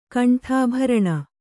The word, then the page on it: ಕಂಠಾಭರಣ: Kannada